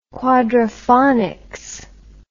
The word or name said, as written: quadrophonics